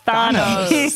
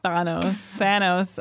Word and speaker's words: Thanos: Thanos. It feels more Greek to me, like Thanos.